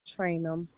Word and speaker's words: Traynham: Trainum. T-R-A-Y-N-H-A-L. – Could you spell that for me again? – T-R-A-Y-N-H-A-M.